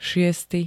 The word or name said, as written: šiesty